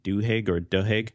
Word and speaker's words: Duhigg: It's The Power of Habit by Charles, I believe you pronounce his last name, Duhigg.